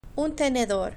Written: un tenedor

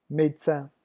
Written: medecin

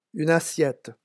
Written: une assiette

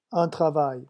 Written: un travail

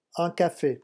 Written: un cafe

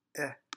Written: epsilon

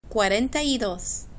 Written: cuarenta y dos

